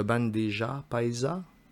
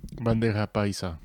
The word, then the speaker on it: bandeja paisa: Sinon, corrigez ma prononciation, mais le bandeja paisa. Bandera paisa.